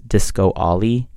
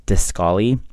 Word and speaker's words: Discolly: People started pronouncing it as Disco Ollie because they thought that there was like the word was like two separate words. But it's actually just Discolly.